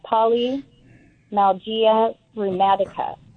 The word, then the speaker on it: polymyalgia rheumatica: I believe it's pronounced polymyalgia rheumatica.